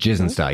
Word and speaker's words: Gizenshaw: So, to take Juno to Gizenshaw Station. – Gizenshaw Station? – Gizenshaw, yep.